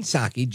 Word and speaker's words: Psaki: And And I think it's interesting, but Jen Psaki, Jen Circle Back Psaki, silent P, of course.